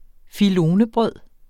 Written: filonebrød

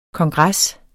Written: kongres

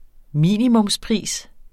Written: minimumspris

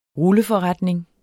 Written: RULLEFORRETNING

